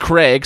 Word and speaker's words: Crag: CR pronounced Craig, so C R A G, but pronounced Craig, uh, was just intense.